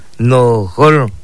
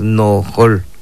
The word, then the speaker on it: nojol: Se pronuncia de esta manera, nojol, nojol, es sur. En lengua maya lo pronunciamos nojol, ahí está.